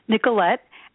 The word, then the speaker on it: Nicolette: Nicolette, and the last name is pronounced Niman.